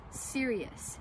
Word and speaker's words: Sirius: The next constellation or Decan constellation that is underneath the main constellation of Gemini is Sirius. Now this is exactly what it sounds like. It sounds like your radio station broadcast thing, Sirius satellite radio. That's exactly how this is spelled.